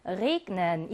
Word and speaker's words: regnen: 그 다음 비가 내리다 라고 하는 동사의 G도 자음 앞에 G가 나와 있지만 G로 발음해서 Regnen 이라고 해요.